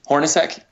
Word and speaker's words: Hornacek: Hornacek.